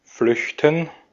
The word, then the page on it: flüchten: /ˈflʏç.tən/